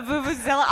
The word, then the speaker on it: vuvuzela: That's a vuvuzela. – A vuvuzela.